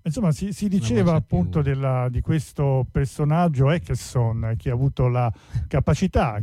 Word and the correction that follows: Åkesson: Ecco, si pronuncia Eckerson o Oxen? – Åkesson, Jimmy Åkesson, ma siete perdonati.